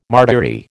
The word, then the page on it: martyry: American English